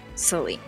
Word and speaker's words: Saline: Saline. – So close, bud. – Well, that's how it's spelled, isn't it? – It's Saline. It's pronounced Saline. – Oh, I put the wrong emphasis on the wrong syllable, you know? – Did you say syllable?